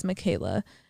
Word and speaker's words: Makayla: My name is Michaela. And Michaela is spelled M-A-K-A-Y-L-A.